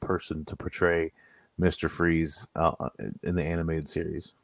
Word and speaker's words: Fries: It's Freeze spelled like fries. So it's F-R-I-E-S.